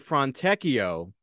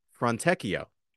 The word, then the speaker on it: Fontecchio: Let's get to take two of Clyde trying to pronounce Fontecchio. – Fontecchio answers right back. Fontecchio is distinguishing himself. – What was that? Fontecchio? – Yeah, yeah. He dissed the R and he added a check instead of the T.